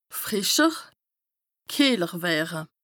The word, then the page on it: frìscher, kìehler wëre: Bas-Rhin; Strasbourg